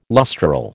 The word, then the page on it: lustral: American English